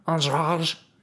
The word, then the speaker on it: Andrade: You know, I don't know why they, I don't speak Portuguese, but, like, they pronounce her name like it's French, you know? Andrade, you know? And, yeah, that last syllable, it's like D-E. It's A-N-D-R-A-D-E. And that D-E at the end there is supposed to be Zh, like the French Zh.